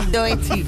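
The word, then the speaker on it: Doechii: A ver, ¿esto cómo se pronuncia? – Doechi. – Muy bien.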